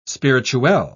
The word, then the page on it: spirituelle: British English